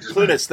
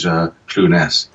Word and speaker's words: Clunes: But yeah, I don't think it's the right or wrong way of saying that. But I think he chooses to use Clunes.